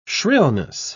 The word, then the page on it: shrillness: British English